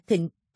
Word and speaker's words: thịnh: Chú thích, Body Sushi hay nữ thể thịnh, âm đọc là thịnh, có nghĩa là mâm đựng, là một dạng nghệ thuật ẩm thực của Nhật Bản đã có trên 1000 năm lịch sử.